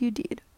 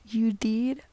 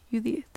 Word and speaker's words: Yudid: My name is Yudid, pronounced Yudid or Yudith, as some of my friends call me. It is not Yudid, although many people have made that same mistake before.